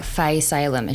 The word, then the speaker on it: Fae Salem: And like, I had an MC once be like, how do you pronounce it? And I was like, Faye Salem.